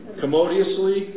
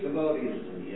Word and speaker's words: commodiously: how do you say this? – I'm sorry. – Commoditiously? – Commodiously? – Commodiously. Yeah, that's a great.